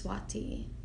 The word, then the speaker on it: Svati: You know, you spell it S-V-A-T-I. So Swati. But it is Swati.